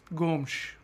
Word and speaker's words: Gomes: André Gomes. Gomes. Apparently, that's the Portugal Portuguese pronunciation. Now, if you're Brazilian Portuguese, it's just Gomes. Gomes. But Gomes. No one's doing it right.